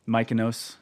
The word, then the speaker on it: Mykonos: Because you go down to Greece, Mykonos. Is that how you say it? Mykonos. – Mykonos. Yeah, Mykonos. You nailed it the first time.